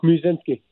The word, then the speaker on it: Muszynski: Is your last name pronounced Muszynski? Do I put the mu on over there, or how do you say it? Yep. Give me the exact saying. – Yeah, that's right. It's Muszynski.